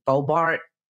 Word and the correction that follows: Boebert: Is that it? Lauren Boebert? – Lauren Boebert.